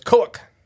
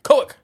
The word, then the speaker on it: Kowuk: I actually have a little pronunciation thing for this one. – Okay. – So that's Kowuk. – Kowuk. – Yeah. – Okay. – You got to say it like Kowuk.